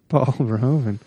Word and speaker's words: Verhoeven: I had to look up the pronunciation. That's exactly how it sounds online. Paul Verhoeven.